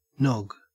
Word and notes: nog: Australian English

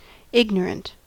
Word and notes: ignorant: American English

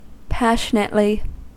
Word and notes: passionately: American English

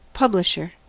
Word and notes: publisher: American English

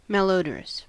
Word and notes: malodorous: American English

